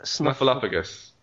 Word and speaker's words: Snuffleupagus: How do you say that? – Snuffleupagus. – Snuffleupagus? – Yes.